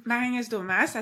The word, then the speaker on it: Marie-Agnès Daumas: Okay, so I'm Marie-Agnès Daumas, as you said, John.